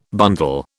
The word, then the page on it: bundle: British English